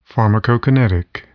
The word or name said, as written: pharmacokinetic